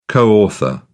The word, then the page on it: co-author: British English